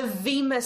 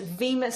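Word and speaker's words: bema: The judgment seat, the Vema seat, spelt with a B, B-E-M-A, but pronounced Vema seat.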